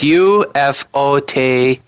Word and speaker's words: Ufotable: It's Ufotable.